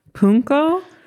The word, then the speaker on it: pwnco: It's called punko.